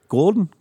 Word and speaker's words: Gould: Actually, stars Elliot Gould. – Gould. – Why did you say it like Arnold Schwarzenegger? – Gould.